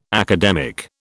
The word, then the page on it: academic: British English